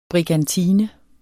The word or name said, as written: brigantine